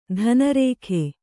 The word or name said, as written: ಧನರೇಖೆ